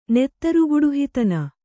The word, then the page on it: ನೆತ್ತರುಗುಡುಹಿತನ: kannada